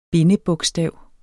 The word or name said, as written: bindebogstav